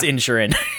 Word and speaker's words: Inisherin: No, there's an I after the N. – It's still pronounced Inisherin.